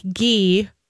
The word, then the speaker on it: ghee: And those are healing fats such as coconut oil, olive oil, avocado oil, and even something like ghee, or G, it's spelled G-H-E-E.